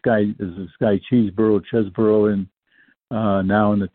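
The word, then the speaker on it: Chesbrough: As a kid, he was always called Chesborough, and that that has been changed by SABR to Cheeseborough. And I I maintain that the old pronunciation is correct. And uh, I there's only one e in there.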